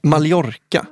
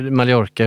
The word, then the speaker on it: Mallorca: För de uttalar det så som det ska uttalas, fast med, på väldigt svenska. Med ett, alltså dubbel L då får det ju vara. Mallorca.